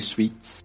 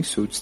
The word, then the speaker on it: Suits: Eu já assisti Suits, que muita gente chama de Suits. Aí uma vez uma mulher me corrigiu, falou que na verdade é Suits. É, é, a pronúncia certa é Suits.